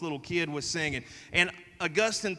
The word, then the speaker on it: Augustine: First one is a guy named Augustine, or some people pronounce it Augustine.